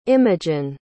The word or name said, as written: imogen